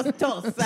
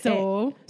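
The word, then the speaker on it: Tso: No, Toast. I say Toast. – How do you say, how do you spell T-S? – So. – So. She says so, too.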